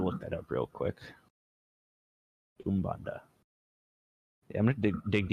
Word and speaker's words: Umbanda: Ubanda? – U-M-B-A-N-D-A, Umbanda.